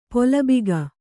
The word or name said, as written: ಪುಲ್ಬಗೆ